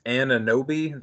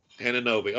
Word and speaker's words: Anunoby: I am pretty sure it's Ananobi. That's how I typically hear it. That's if I phonetically sound it out. A-N-U-N-O-B-Y. Ananobi. – Ananobi.